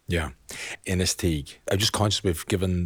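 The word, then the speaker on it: Inistioge: Yeah, you get all sorts of pronunciations, but the locals, we call it Innisteague.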